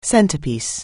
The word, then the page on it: centrepiece: anglais